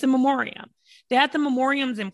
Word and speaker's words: memoriam: Like it's a pandemic and I get like, they had those memorial. I'm saying that wrong. It's a memoriam.